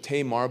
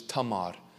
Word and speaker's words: Tamar: Last week, you and I candidly discussed the tragic desecration of one of David's precious daughters named, I used the pronunciation of Tamar, but in actuality it is Tamar.